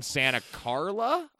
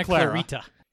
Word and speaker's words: Santa Carla: Booming around Santa Carla? I will say it wrong at least once in this episode. – Yeah, it's not Santa Clarita. – Not that either.